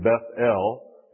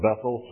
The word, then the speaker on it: Beth El: We call it Bethel sometimes, but it's probably more correctly pronounced Beth El.